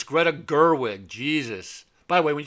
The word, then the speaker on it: Greta Gerwig: Greta Gerwig.